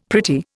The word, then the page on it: pretty: Indian